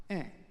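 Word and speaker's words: Ng: And the tricky part was he had two letters, no vowels, an N and a G. And he said to Bill, "It's pronounced eh."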